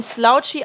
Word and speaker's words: Slouchy: Ah, okay, das OU in Slouchy aussprechen wie in laut. Dann ist es Slouchy. – Also, ja. – Woman's Slouchy. Vielen, vielen Dank. ja. Slutty klingt dann Slutty ist falsch.